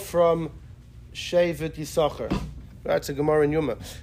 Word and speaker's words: Yisachar: And since Kabbalah's Nister is hidden, therefore we don't pronounce the second Shin. That's what I think the Rebbe quoted from his father, the Blavik. So in Lubavitch, we always just say Yisachar, always.